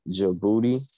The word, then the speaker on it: Djibouti: and Djibouti.